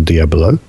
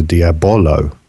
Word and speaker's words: Diabolo: Oh, you're saying Diablo. This is a Diabolo. – I didn't say Diabolo, did I? I said Diablo. – Yeah, but Diablo is how you say it when you say it quickly.